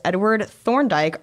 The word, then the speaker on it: Thorndike: 19th century psychologist Edward Thorndike argued that behaviors that give... – Edward Thorndike. – Impressed that I said it right the first time, because that's a very weird combination of sounds and letters.